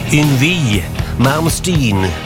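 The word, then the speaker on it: Yngwie Malmsteen: Yngve Malmsten. Eller som man säger där borta. Yngve Malmsten. Det var svårt att uttala hans namn där i början av hans karriär, har han berättat.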